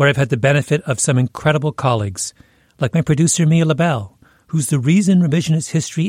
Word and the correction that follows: Lobel: You know it's actually pronounced Lobel, right, Malcolm? – But how do you find people like Mia?